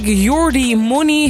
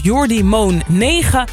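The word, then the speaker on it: Jordymone9: Zo had ik Jordi Money heel lang uitgesproken als Jordi Moon 9. Totdat een luisteraar mij daarvan op de hoogte stelde.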